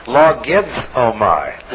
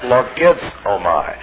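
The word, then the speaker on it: Logizomai (λογίζομαι): L-O-G-I-Z. And it's, the Z is pronounced Z, like in suds. Logizomai. Logizomai.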